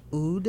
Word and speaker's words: oud: Spelled O-U-D? – It's spelled a different way because in Arabic, the first letter is very hard to pronounce in other languages. – Okay. – We call it oud. So, ayn. So this pronunciation is very unique, and you cannot pronounce it with the other languages.